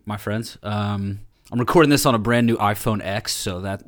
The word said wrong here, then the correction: iPhone X: iPhone what? – X10. – Oh, okay. Good. Okay. Just checking. – The police are back. The pronunciation police. I'm sorry. – I thought I'd invented a new phone or something, but you were talking about the iPhone 10.